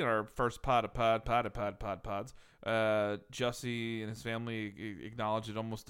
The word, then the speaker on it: Jussie: I think it's Jussie. – I always thought it was Yussie or Jussie. – It's not like yogging, you idiot.